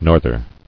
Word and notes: Norther: north·er

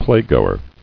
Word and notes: Playgoer: play·go·er

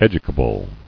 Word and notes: Educable: ed·u·ca·ble; Ed"u*ca*ble

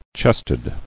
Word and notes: Chested: chest·ed

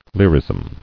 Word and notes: Lyrism: lyr·ism

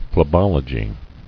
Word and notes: Phlebology: phle·bol·o·gy

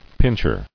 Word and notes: Pincher: pinch·er

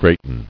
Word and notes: Greaten: great·en

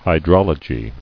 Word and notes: Hydrologist: Hy*drol"o*gist

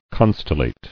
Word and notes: Constellate: con·stel·late

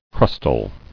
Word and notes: Crustal: crust·al

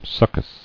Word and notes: Succus: suc·cus

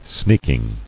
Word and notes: Sneaking: sneak·ing

